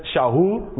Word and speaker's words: Shaul: so you'll notice instead of saying Saul, it says Shaul, which is of course his Hebrew name, the way to pronounce it.